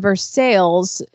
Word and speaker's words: Versailles: And I think the monument that's in Versailles, and if anybody is thinking I'm pronouncing it wrong, that's how they say it.